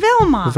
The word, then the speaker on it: Wilma: Vilma. – Vilma. – Yeah. She spells Vilma. It with a W. – Oh, sure. Like Wilma. – Wilma. Yeah. But they pronounce it Vilma.